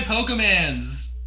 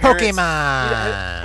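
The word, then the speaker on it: Pokémon: It has more to do with, like, little kids running up to their parents and saying, let me show you my Pokemans.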